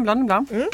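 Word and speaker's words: Blanc de Blancs: Och jag är jättedålig på att uttala namn så, men Pierre Peters Blanc de Blanc, eller hur? – Ja, Blanc de Blanc.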